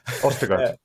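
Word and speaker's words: Østigård: I can't even pronounce his name. – Ostergaard. – Thank you.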